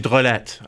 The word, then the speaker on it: Drolet: And Drole is spelled D-R-O-L-E-T, right? Not a bunch of E's at the end. It's the T is silent, basically.